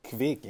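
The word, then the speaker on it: Kveik: Norwegian Kvik yeast. You know if I said that properly? – Kvik, yes.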